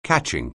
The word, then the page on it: catching: anglais